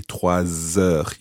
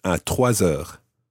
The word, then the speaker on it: trois heures: And you, again, because you're following it with a vowel sound, albeit a silent H, then you say trois heures. You run the S of trois into it. À trois heures.